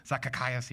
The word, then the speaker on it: Zacchaeus: Zacchaeus, yeah.